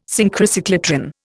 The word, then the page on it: Synchrocyclotron: Indian